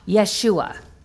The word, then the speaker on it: Yeshua: In Hebrew, the name Joshua is pronounced Yeshua.